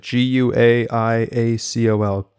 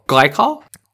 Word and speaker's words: guaiacol: G U A I A C O L Guaiacol. Clint, have you heard of this? Okay. Well, glycol? It's not glycol, I can tell you that.